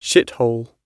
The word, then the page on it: shithole: British English